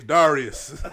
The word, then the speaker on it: Darius: My name's Darius. – Darius, nigga. – You better say it right. – It's a soft A.